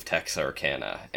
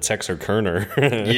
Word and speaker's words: Texarkana: Well, I actually have a friend from Texarkana, I totally slaughtered the pronunciation of the town, and I just called it Texarkerner.